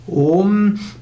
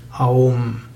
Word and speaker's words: Om: Om besteht eigentlich aus drei Silben und dem Nachklang danach A, U und M. Und äh, wird aber trotzdem ausgesprochen Om, denn A und U wird hier zusammengefasst zu O. Deshalb sagt man tatsächlich Om und nicht Aum.